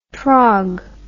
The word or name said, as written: trog